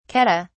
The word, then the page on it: keddah: British English